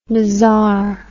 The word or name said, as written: mazar